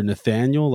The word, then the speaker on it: Nathaniel: It's either Nathaniel, like the full name, all three syllables, or Natty.